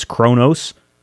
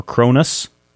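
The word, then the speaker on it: Kronus: His name is Kronos, or Cronus, depending on how you want to pronounce it or how you recognize it.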